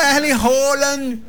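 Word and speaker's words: Erling Haaland: Ô, Erle Haaland! Não, porque é assim que pronuncia.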